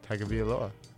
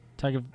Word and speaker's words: Tagovailoa: Tagovailoa. – Tagovailoa.